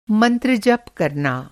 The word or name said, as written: मंत्र